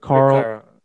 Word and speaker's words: Carlisle: Rick Carlisle. – There's like an S and an I-L-E. – Rick Carlisle. – Rick Carlisle. It sounds like there's a W in there. Rick Lyle. – Rick Carlisle.